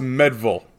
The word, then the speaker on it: Meadville: M-E-A-D. – Is it Medville? But I think it's Medville? Pronounced Medville.